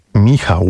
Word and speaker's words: Michał: How do I pronounce your name? – To Gerald Murnane. Cześć, Michale. Jak wymawia się twoje imię? – Michał.